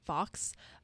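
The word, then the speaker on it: Fuchs: Because our president is called President Fox at UF, and it's spelled F-U-C-H. – So everyone wants to say the F bomb.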